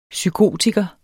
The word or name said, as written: psykotiker